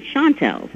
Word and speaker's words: Chantels: So we said, what about the Chantels? But we pronounce it Chantels.